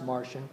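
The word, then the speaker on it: Marcion: His name's Martian, not spelled that way, but it's pronounced Martian.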